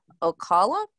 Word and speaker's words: Ocala: So from 1980 to 1983, Bobby began to terrorize the Florida communities of Miami, Ocala, is that how you say it? – That's how I would say it.